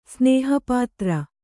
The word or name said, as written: ಸ್ನೇಹಪಾತ್ರ